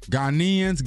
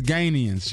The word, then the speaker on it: Ghanaians: Ghanaians, Ganians.